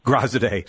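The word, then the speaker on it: Graziadei: How do you pronounce it? I'm sorry. – Grazide.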